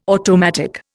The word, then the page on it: automatic: Indian